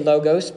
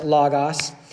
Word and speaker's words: logos: Some people say logos, but I was taught it's actually pronounced logos.